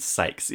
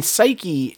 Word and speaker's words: Psyche: I've always said Sykes. It's P-S-Y-C-H-E, right? And so you can pronounce it either way. – Yeah, I mean, Psyche, that's how you'd say Psyche.